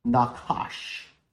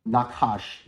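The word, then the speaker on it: nachash: Now, the word serpent here, when you go to the Hebrew language, it is spelled N-A-C-H-S-H, Nakash, okay? Nakash, that is the word for serpent.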